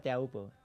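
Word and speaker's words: Teahupoo: Creo que una de tus mejores olas se llama, a ver si lo digo bien, Tihupu, ¿o cómo es? Sí. ¿O cómo lo dices tú? – Sí, se llama Teahupu.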